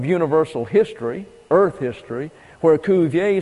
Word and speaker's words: Cuvier: And Cuvier is his correct pronunciation. Cuvier is the anglicized pronunciation.